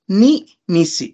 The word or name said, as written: ni misi